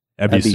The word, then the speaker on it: Ebisu: But yeah, even when it's spelled that way with the old katakana, with, or in English with the Y at the beginning, it's still pronounced Ebisu.